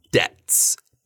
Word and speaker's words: debt: That's right, it's debts, debts. So it's the same pronunciation as bets, for example.